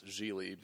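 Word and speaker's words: Gigli: And, you know, we watched the movie, so now we know how to pronounce Zhili because it rhymes with really.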